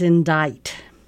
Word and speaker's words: indict: Our next word is indict. – Ooh. I-N-D-I-C-T. – Correct. Silent T, right? – Yeah.